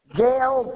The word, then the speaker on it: Gail: Gail Grove. – How do you spell that? – G-A-I-L, like the storm. Grove, like Willow Grove Park. – G-R-O-V-E? – Yes, ma'am.